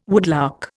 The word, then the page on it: woodlark: Indian